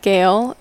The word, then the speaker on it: Gale: And I would like to say that Gale is spelled like the Gale Force Wind, G-A-L-E. A lot of people, that's how our last name is actually spelled, like the wind.